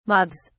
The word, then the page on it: moths: British English